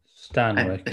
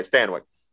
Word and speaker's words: Stanwyck: That's how you say your last name, right? – Stanwyck. – I think it's Stanwyck. – Stanwyck. Okay, Stanwyck.